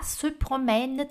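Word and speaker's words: promènent: Se promène, terceira pessoa do, do plural, tem escreve com ENT, mas não fala, tá? Não pronuncia esse NT aqui.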